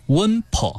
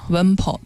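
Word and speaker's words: 榅桲: 对，这个水果呢就叫做 Wenpo 啊。 – Wenpo 对啊。 – Wenpo 呢又叫木梨啊，在我们新疆呢又叫做新疆木瓜，主要种植于我们喀什和田地区。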